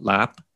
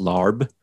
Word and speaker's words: larb: I also like what's called lap. You know, I think a lot of people, when they go to a Thai restaurant, it looks like it's pronounced larb. – Yeah. – I think on one of the Spider-Man movies, like Aunt May and Peter Parker go eat Thai and they even order larb. And I'm like, that's not how you say it. But to be fair, that's the way it's spelled, right?